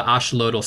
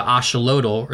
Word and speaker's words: axolotl: But in Mexico, the X is actually pronounced as a SH. So like the place where the axolotl or the axolotl are living is a place called Xochimilco, but it's spelled X-O, like Xochimilco.